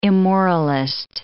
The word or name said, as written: immoralist